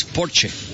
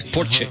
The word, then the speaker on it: Porsche: Y vino el director de Porsche al programa y dijo, es Porsche, no Porsche. Porque en Alemania pronuncian todas las letras, es Porsche.